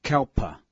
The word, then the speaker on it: kalpa: 刹那梵文读作 kshana，一刹那是表示极短的时间，劫梵文是 kalpa，是代表极长的时间。